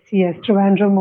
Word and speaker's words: Trivandrum: What was it like for you growing up in, am I saying it correctly, Trivandrum? – Yes, Trivandrum or Thiruvananthapuram.